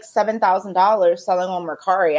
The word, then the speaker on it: Mercari: She sells on Poshmark, but she sells more on Mercari.